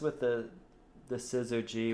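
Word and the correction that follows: Syzygy: which is, did I say it right? – Sigisi. Trust me, I had to ask Google how to pronounce it.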